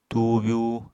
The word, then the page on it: tuubyú: apatani